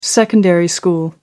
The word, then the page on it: secondary school: British English